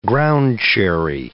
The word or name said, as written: ground-cherry